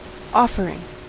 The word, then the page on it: offering: American English